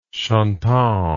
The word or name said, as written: chantant